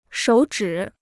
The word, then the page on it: 手指: shǒu zhǐ